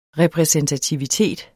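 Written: repræsentativitet

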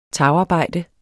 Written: tagarbejde

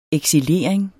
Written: eksilering

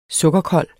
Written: sukkerkold

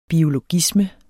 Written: biologisme

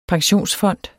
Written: pensionsfond

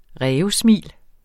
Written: rævesmil

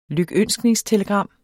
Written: lykønskningstelegram

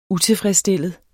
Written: utilfredsstillet

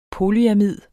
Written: polyamid

